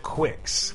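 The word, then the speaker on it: Qix: Kicks for Game Boy. I give it an A. Nintendo. – Kicks for Game Boy. – Kicks. – I always pronounce it Quix, because it's Q-I-X.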